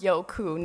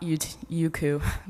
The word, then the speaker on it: Youku: Youku is basically like YouTube, and it's pronounced Youku, not Youku, by the way.